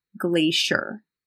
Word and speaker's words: glacier: We pronounce the C as a sh sound. Glacier.